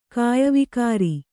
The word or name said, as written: ಕಾಯವಿಕಾರಿ